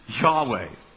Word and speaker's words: Yahweh: That is our translation of the Hebrew word that we believe is pronounced Yahweh.